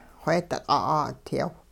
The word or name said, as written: xweedi-q' 'aa'udyaw